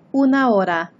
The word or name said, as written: una hora